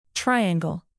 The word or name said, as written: triangle